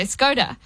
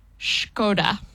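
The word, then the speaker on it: Škoda: People always say Skoda. – Yeah. – And I didn't know this. Apparently it's pronounced Skoda.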